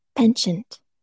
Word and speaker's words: penchant: Because I don't, is that Pichon? That does look French. Let's look it up. Is that a word? Pichon. – Penchant.